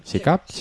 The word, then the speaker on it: SICAPs: ¿Lo he dicho bien? ¿SICAPs? – Sí, sí, sí.